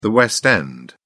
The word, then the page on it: West End: British English